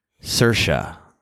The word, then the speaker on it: Saoirse: I'll just go out that away, and it's pronounced Saoirse. Saoirse. Saoirse Ronan.